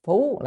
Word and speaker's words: phú: Hội sớ viết, tức là trong sách hội sớ ghi hộ vị phú hộ, chữ này đọc là phú là che, che chở đó, phú hộ tức là che chở, hộ là che chở.